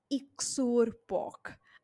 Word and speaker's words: iktsuarpok: I'm just going to pronounce it for you in case you want to read more about it. It is I K T S U A R P O K or P O H K.